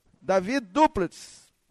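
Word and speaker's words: David du Plessis: Como é que fala? Davi Duplitz.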